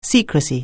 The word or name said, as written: secrecy